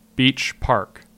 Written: Beach Park

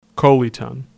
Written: Coleta

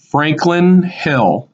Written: Franklin Hill

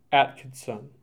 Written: Atkinson

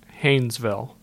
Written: Hainesville

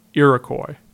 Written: Iroquois

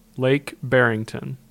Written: Lake Barrington

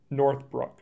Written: Northbrook

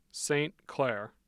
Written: St. Clair

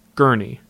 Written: Gurnee

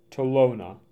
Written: Tolono

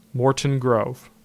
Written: Morton Grove

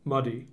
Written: Muddy

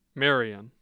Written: Marion

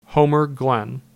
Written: Homer Glen

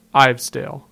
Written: Ivesdale

